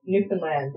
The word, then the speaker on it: Newfoundland: Newfoundland, it rhymes with understand.